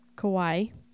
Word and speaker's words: Kauai: I don't know how to say it. – South shore of Kauai. – Kauai. – Jimmy. – I thought you pronounce every vowel. That's how you like do Hawaiian words.